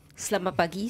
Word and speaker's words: Selamat pagi: Selamat pagi semua orang di luar.